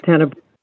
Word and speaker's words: Tenebrae: Tenebrae.